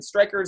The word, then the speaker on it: Stryker: Stryker, at Steeler Nation Stryker, on Instagram and Twitter as SNStryker, and Stryker is spelled with a Y.